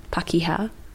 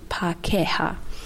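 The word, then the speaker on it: Pākehā: But my wonderful friend, she pointed out to me that I wasn't pronouncing Pakeha right. So before I used to say Pakeha, like Pakeha, I just kept saying that, but actually it's Pakeha.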